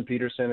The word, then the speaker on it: Petersen: Austin Peterson is my Peterson's my last name, and it's spelled S-E-N.